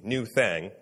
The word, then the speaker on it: Nuthang: This album is spelled N-U-T-H-A-N-G, New Thang. So that's why I'm saying it like that.